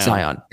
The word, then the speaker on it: Sion: Sion.